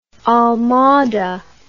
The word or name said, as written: Almada